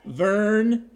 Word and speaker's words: Vern: Vern is my first name. That's spelled V as in Victor, E-R-N, Burton.